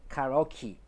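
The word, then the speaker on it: karaoke: すけれどもカラオケカラオケねカラオケも最後敬意で終わってますんでまあ大体の英語スピーカーの方はカラオキカラオキというふうに言いますね。えー、だから敬意はキーという発音になるんだなっていうことで。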